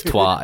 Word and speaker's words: Troyes: And the last one looks like a person's first name pluralized, but it's not pronounced that way because it's French. – Yeah, I have no idea. – Troyes. – Or Twa. Oh, yeah, Troyes. It's Twa.